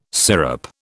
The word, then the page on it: sirup: British English